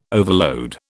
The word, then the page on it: overload: British English